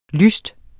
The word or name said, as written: lyst